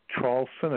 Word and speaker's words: Traulsen: If you Google my last name, it's Traulsen. It's spelled T like Tom, R-A-U-L-S-E-N like Nancy.